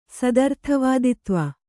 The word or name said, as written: ಸದರ್ಥವಾದಿತ್ವ